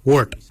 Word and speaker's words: word: Yet is spelled Y-E-D. Yet. Yet is your chin. For throat, we say wort. For throat, we say wort. Wort is spelled W-O-R-D. Wort.